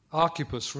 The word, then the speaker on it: Archippus: Archippus. Remember there that CH is pronounced K like lock, like a Scottish lock.